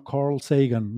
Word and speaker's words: Sagan: Em, eh, Sagan eh, o, eh, Sagan, yo suelo decir Sagan porque es como en español mm, se nos ha quedado esa, esa pronunciación, ¿no? Pero en él, cuando él dice su nombre, dice que se llama Carl Sagan, ¿no? Esa es la, la pronunciación así inglesa, pero bueno, para entendernos.